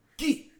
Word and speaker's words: gi: Gi.